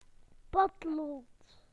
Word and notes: pencil: British English

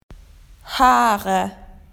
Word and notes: hair: Australian English

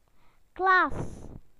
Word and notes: classroom: British English

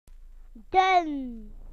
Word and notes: thin: British English

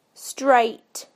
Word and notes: straight: British English

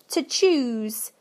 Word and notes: to choose: British English

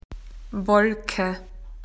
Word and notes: cloud: Australian English

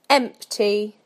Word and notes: empty: British English

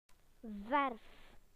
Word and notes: paint: British English

